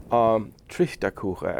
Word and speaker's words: Trichterkuchen: Trichterkuchen, as it's pronounced in Pennsylvania Dutch.